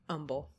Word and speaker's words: Humble: Old Humble? – No. Humble. The H is silent. Humble.